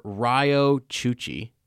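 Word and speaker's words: Riyo Chuchi: Now, Inter-Senator Ryo Chuchi. Did I say that right? – Yes, you did.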